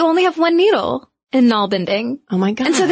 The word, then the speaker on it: nalbinding: And I gotta tell you, side note, when I first read the word nalbinding, which listeners is spelled N-A-L-B-I-N-D-I-N-G, I immediately thought of narwhals.